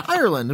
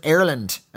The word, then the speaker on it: Ireland: He gives his Twitter handles, Sinful Spectrum and Doctor Who _ the 1990s on Twitter from Ireland. And by the way, we pronounce it Ireland and not Ireland, as you Americans say. We pronounce it with a long American I.